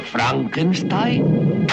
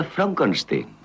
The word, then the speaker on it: Frankenstein: ¿Doctor Frankenstein? Frankenstein. ¿Me toma el pelo? No, se pronuncia Frankenstein.